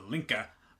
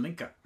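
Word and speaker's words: Linke: Yeah, remember, it's Link with an E. – Yes. – As I'm always saying. – I've thought about just pronouncing as Otto Linka, but then people would be like, that's a kind of odd last name. How do we spell that? – So. You know, that's the German pronunciation, yeah.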